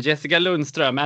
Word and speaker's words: Jessica Lundström: Marry Me was composed by you, Erik Nyholm, Kristoffer Karlsson and Jessica Lundström. And I guess here I gave them very Swedish pronunciations of the names. – Yep, that's true.